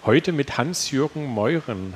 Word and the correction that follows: Meurer: Hallo. Meurer. Meurer. – Meurer, richtig. wow.